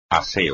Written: aseo

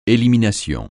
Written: élimination

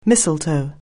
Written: mistletoe

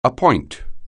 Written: appoint

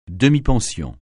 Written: demi-pension

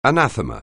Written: anathema

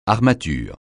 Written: armature

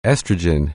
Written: estrogen

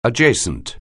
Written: adjacent

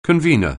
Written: convener

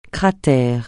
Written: cratère

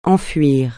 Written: enfuir

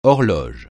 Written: horloge

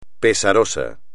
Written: pesaroso

